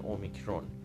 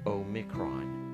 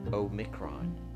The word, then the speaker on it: Omicron: ولی من فقط میخواستم که تلفظش در زبان انگلیسی این رو ادا کرده باشم برای اینکه ما تلفظ انگلیسیش رو هم داشته باشیم اومیکرون اومیکرون این رو فقط از این جهت خواستم بگم